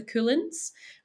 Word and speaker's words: Cuillins: It's also a slight play on the fact that we are situated just in front of the Coulins, which is our mountain range, which is spelled C-U-I-L-L-I-N-S. So it also just sounds like the word cool, which I love.